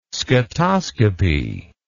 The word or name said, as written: scatoscopy